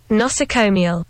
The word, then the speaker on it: nosocomial: Nosocomial. – There we go. – Nosocomial.